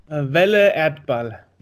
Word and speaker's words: Welle Erdball: Welle Erdball.